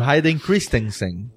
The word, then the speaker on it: Hayden Christensen: E a última identidade Jedi que as pessoas lembram é com, quando ele era o, o Hayden Christensen, Christensen, né? Como é que fala? – Sim, assim? o Hayden Christensen.